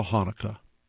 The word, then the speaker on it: Hanukkah: There is no right way to spell Hanukkah. That's because it's a Hebrew word beginning with the consonant het, which has no English equivalent. The closest is probably the throaty k sound at the end of Bach.